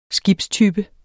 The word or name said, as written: skibstype